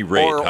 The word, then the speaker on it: Raitt: So it's Bonnie Raitt, Or perform music. He didn't write the music, but he performed it. – A little controversial, that movie. Bonnie Raitt, huh? It's not Bonnie Raitt? Because it's spelled rat with two T's, right? – R-A-I-T-T.